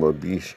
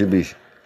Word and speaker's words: Ibushi: Oh, we just had a beautiful drop kick from Ibushi. It's Ibushi. It's Ibushi. I apologize for mispronouncing the name.